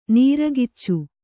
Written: ನೀರಕಿಚ್ಚು

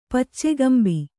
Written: ಪಚ್ಚೆಗಂಬಿ

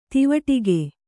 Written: ದೀವಟ್ಟಿಗ

